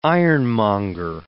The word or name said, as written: ironmonger